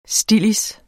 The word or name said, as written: stillids